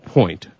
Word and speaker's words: Poynt: And this is not spelled, it is pronounced Point, correct? – You got it. – Okay, so it's spelled, however, P-O-Y-N-T.